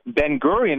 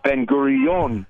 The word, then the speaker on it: Ben-Gurion: Sometimes I tell people it was shocking me to discover the name of the airport isn't Ben-Gurion Airport. It's Ben-Gurion. – Yes. – That's how Israelis pronounce it.